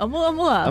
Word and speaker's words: Oumuamua: How do you pronounce it? – Oumuamua. – Oumuamua, of course.